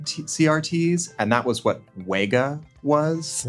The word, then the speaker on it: Wega: That's the one that it kind of looks like Vega, but with two V's. And Sony's always like, no, it's Wega. Say Wega.